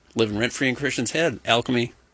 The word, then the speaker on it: Alkeme: And it's, I believe it's pronounced Alchemy, would be my guess. – Like A-L-K-E-M-E.